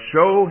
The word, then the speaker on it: shew: The Bible says, "A man that hath friends must show himself friendly." Now the word there, shew, or S-H-E-W, I've been told by different folks is pronounced show, so I'm going to take their word for it, but that's what it's talking about there, what we would say show.